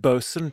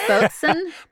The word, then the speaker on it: boatswain: It's pronounced bosun, just so you know. – Bosun? – Bosun, just bosun.